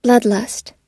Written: bloodlust

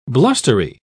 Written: blustery

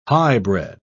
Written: highbred

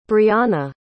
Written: breahna

